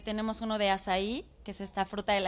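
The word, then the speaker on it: açaí: Se escribe acaí y se pronuncia azaí para que sí la encuentren.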